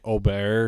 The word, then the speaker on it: Aberg: It's like, it looks like Aberg. – Is it Aberg? – It's like Ober or something. Yeah. That's how they pronounce it.